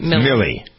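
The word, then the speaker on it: Milley: All right, while we're digging around over there, General, is it Miley? How do you pronounce it? Millie or Miley? – Millie.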